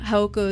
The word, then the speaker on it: Jaojoco: But yeah, Haoko is spelled JAOJOCO. I know it doesn't sound like that, but that's what it is.